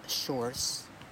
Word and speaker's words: Shores: Yeah, my name is Shores, or it's actually pronounced Shores. And but I always tell people that it's like the seashore, so people can easier remember my name.